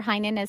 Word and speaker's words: Heynen: Remember, Heinen is spelled H-E-Y-N-E-N.